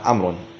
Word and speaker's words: عمرو: طبعا عمرو هذا من الأخطاء الشائعة أن يقال عمرو. هذه الواو تكتب ولا تلفظ في علم الإملاء.